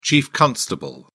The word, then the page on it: chief constable: British English